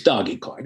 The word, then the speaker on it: Dogecoin: Do you pronounce it Dogecoin or Doggycoin? – That's a good question. I I say Dogecoin. – No, that's, you're wrong. It's Doggy. – Am I? Okay. – It's Dogecoin. It's Dogecoin.